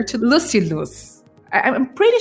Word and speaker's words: Lucilius: Yeah, you know, I think as long as people understand it, then you can pronounce it any way you like. I usually say Lucilius, but yeah.